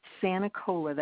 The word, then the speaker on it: Sanicola: Nancy Sanicola, that's spelled S-A-N-I-C-O-L-A.